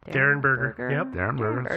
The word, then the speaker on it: Derenberger: Derenberger. – Sounds right. – Yep, that's how he pronounced it.